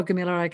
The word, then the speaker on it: Gamilaroi: But at Palima, I was blown away by the presentation by Rhonda Ashby and Bernadette Duncan for Gamilaroi, yep. – And I'm probably mispronouncing that because I know there's a few pronunciations. – Yep. Yeah, no, you got it. – For Gamilaroi country that, you know, basically they had, you know, basically those languages, excuse the language, were stock whipped out of people.